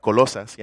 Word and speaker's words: Colosas: And I want to say it right, Colosas, yeah, in Spanish.